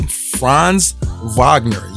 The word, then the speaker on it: Franz Wagner: Franz Wagner. Yes, I'm saying that correctly.